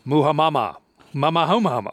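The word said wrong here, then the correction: muhammara: How do you say that? – What are you talking about? – I thought it was Muhamara, but... – Muhamara. I forgot an R.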